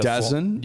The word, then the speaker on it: DAZN: I guess it's a DAZN. – It was called DAZN. Like that's how it's pronounced.